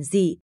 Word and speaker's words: dị: mà chỉ có nghĩa là giản dị, và chữ dịch phải đọc là dị, nghĩa là dễ dàng.